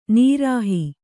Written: ನಿರೀಹ

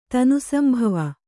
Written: ತನುಸಂಭವ

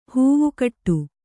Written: ಹೂವುಕಟ್ಟು

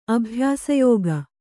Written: ಅಭ್ಯಾಸಯೋಗ

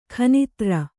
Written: ಖೂನದೋರು

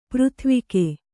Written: ಪೃಥ್ವಿಕೆ

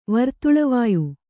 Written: ವರ್ತುಳವಾಯು

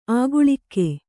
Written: ಆಗಳಿಕೆ

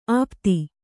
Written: ಆಪ್ತಿ

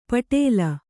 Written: ಪೆಟಲ

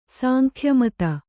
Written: ಸಾಂಖ್ಯಮತ

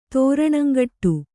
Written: ತ್ರಾಣಂಗೆಡು

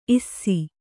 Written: ಇಸಿ

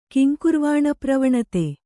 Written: ಕಿಂಕುರ್ವಾಣಪ್ರವಣತೆ

